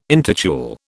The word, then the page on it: intitule: British English